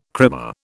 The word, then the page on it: krimmer: British English